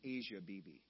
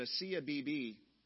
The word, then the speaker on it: Asia Bibi: Sometimes I pronounced her name Asia Bibi because it's spelled just A-S-I-A. But Asiya Bibi, praise God, just this last week was able to finally leave the country of Pakistan.